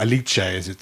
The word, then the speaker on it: Alice: And Alice, or Alice, as it's pronounced in Italian, worked very closely with...